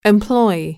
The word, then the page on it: employee: [implɔ́iiː]